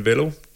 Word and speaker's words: Wello: Men Wello, W-E-L-L-O, ligesom hello, bare med W.